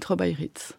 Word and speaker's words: trobairitz: lui dit qu'elle s'exprime aussi bien qu'une trobaïrite. – Trobaïrite, ça s'écrit T-R-O-B-A-I-R-I-T-Z.